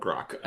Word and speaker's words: Grok: Is it Grok or is it like Grok like Rock? – Yes. – Okay. – Yes, it is. It is in fact called Grok.